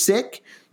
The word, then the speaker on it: Sikh: And so same name, the proper pronunciation, as far as we can tell based on our research, is Sikh, but we may at some point say Sikh, just because hearing that for me 30 years of my life, and now trying to get it correct, so we may bounce back and forth, but the correct pronunciation is Sikh, and we will try very hard to say Sikh.